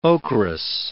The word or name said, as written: ocherous